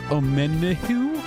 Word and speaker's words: Omenihu: Well, Chiefs defensive end Charles Omenihu? I might have butchered that. – You got it.